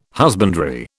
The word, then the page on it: husbandry: British English